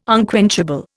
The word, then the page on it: unquenchable: Indian